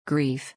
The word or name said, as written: grief